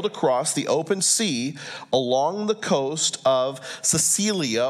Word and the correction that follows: Cilicia: or Cilicia is how it's pronounced, "and Pamphylia, we came down to Mura in Lucia.